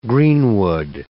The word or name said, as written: greenwood